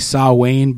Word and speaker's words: Samhain: I think the pronunciation of that word is supposed to be Samhain, but it's spelled Samhain, and they make the character as Sam Hain as two words.